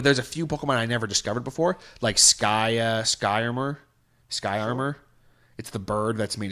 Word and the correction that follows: Skarmory: Armor. Skarmory, that's it.